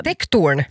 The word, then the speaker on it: Tektorn: Och det här är alltså ett par som bor, heter det, nu, om jag uttalar det rätt nu, heter det Tektorn? – Ja, Tektorn.